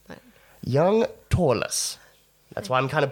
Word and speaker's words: Törless: Törless. And there is an umlaut. I'm pronouncing it weird because there's an umlaut above the O.